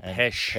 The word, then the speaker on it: Pest: Yes, they pronounce a lot of times. You're no Hungarian person because a lot of things finish with jush. – Mm. – So Budapest. – Oh, Pesh.